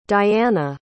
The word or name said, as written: Dyana